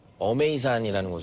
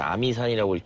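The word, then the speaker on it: 어메이산이라는 (峨眉山): 아, 저는 지금 어메이산이라는 곳을 갑니다. 한자로는 이제 아미산이라고 읽기도 하는데, 무협 소설을 읽다 보면 소림, 우당, 아미파라는 게 있습니다.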